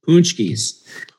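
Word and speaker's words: pączki: And I see on the slide you also have a Polish treat there that is spelled kind of weird, but is actually pronounced punchkis.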